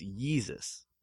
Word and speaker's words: Yeezus: No, it's W-E-S-U-S. Instead of Jesus, it's Yeezus. And it's called the Yeezus Tour.